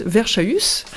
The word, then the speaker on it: Verschaus: qui se prononce comme Emmaüs, j'ai eu la révélation là tout à l'heure, Emmaüs, Verschaus, Verschaus, qui veut dire probablement versant chaud.